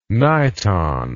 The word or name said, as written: niton